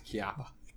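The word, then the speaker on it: Chiappa: I think the H is silent, guys. Come on, let's be real. It's Chiapa.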